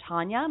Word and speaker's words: Taunia: You can find me almost everywhere using the handle Diva Taunia. My name is spelled T-A-U-N-I-A.